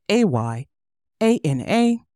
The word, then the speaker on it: Ayana: Instagram is Ayana Explains It All with an underscore in between every word, and my name is spelled A Y A N A.